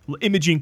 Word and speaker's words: Imogen: She might be pronouncing it correctly. Imogen. – I think so. Imogen Poots. – Yeah, she said Imogen. Yeah, maybe we should take after her.